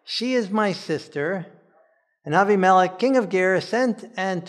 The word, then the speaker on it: Abimelech: So our topic this week is Abraham and Abimelech, or maybe better pronounced, Avimelech. And if we're going to pronounce Avimelech as Avimelech, maybe we should have been pronouncing Abraham as Avraham.